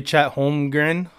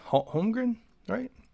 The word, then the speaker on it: Holmgren: But first play is going to be Chet Holmgren. Holmgren, right? That's how you say it, I think.